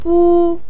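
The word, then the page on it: bu1: tone 1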